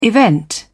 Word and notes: event: British English

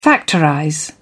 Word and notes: factorize: British English

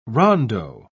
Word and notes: rondeau: British English

